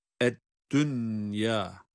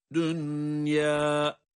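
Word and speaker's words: dünyâ (دنيا): Bunlarda idgam-ı ma'al gunne olmaz. Bunlar izhar ile okunur. Çünkü burada cezimli nun ile ya harfi aynı kelimede gelmiştir. Bu tür yerlerde okuma izhar iledir. Yani ed-dünya şeklindedir.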